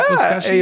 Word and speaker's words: Buzkashi: How did you say it? – Buzkashi? – Yeah, Buzkashi? Your pronunciation is really good.